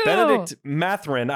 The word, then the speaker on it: Benedict Mathurin: Benedict Mathurin.